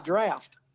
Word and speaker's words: draught: And I almost said, you mean Side Draft. – Yeah. Well, but, draft is spelled drought, right? – I guess. – D-R-A-U-G-H-T. That's traditional spelling of draft beer. – Oh, is it really? – D-R-A-U-G-H-T.